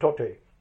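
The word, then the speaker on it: Tauktae: named it Tote. It is spelled as Tokte, but basically it's a Myanmar word, and we happen to call it Tote, and that got formed, but it intensified heavily, and then of course it took its path.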